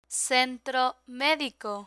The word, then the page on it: centro medico: spanish